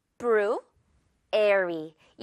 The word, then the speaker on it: February: 이 발음, 이 단어 자체가 February. 이렇게 발음할 수도 있고요. 이 brew 가운데 부분은 뷰라고 발음을 해버릴 수도 있어요. 워낙 빠르다 보니까 February. 이렇게요. February.